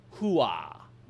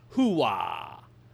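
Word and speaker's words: Hooah: is an actual United States Army battle cry, although he is saying it wrong. He places far too much of a W sound on the second syllable. The real version is closer to "Hoo-ah," not "Hoo-ah."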